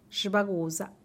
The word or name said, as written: xubagusa